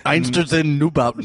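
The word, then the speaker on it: Einstürzende Neubauten: Einsters in Neubauten.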